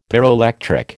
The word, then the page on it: pyroelectric: American English